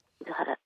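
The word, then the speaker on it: 厳原: はい、もう今朝私が暮らす伊豆原町エリアのポイントを紹介したいと思います。 – はい、伊豆原は厳しいという字に原っぱの原っていう字で伊豆原って読むんですけれども、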